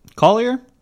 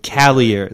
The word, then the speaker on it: Callier: Before that was Oh Dear, What Can the Matter Be by Terry Collier? – Callier. – Yeah, Callier. That's how you say it.